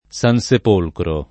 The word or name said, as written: Sansepolcro